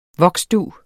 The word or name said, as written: voksdug